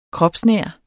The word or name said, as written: kropsnær